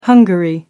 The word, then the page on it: hungary: American English